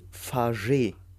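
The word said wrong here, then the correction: Fargeat: Oder Fajat, genau. Eben noch extra nachgeguckt.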